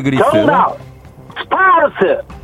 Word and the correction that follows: 스파르타: 자, 다시 한번 정확하게 뭐라고요? – 스파르타. – 예, 스파르타요. 네. 스파르스 아니고 아.